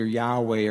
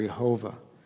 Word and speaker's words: Yahweh: which is pronounced either Yahweh or Jehovah, to Moses from the midst of the burning bush back in Exodus 3.